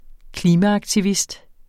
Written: klimaaktivist

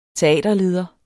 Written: teaterleder

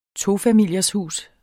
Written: tofamilieshus

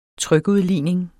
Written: trykudligning

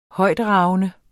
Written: højtragende

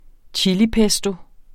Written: chilipesto

